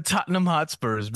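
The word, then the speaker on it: Tottenham Hotspur: How is it pronounced? – The Tottenham Hotspurs, man.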